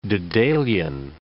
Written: Daedalean

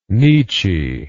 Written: nitchie

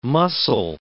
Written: mussel

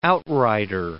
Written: outrider

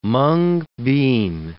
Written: mung bean

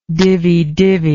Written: dividivi

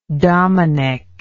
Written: domenic

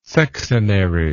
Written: sexenary

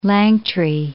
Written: Langtry

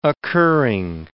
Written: occurring

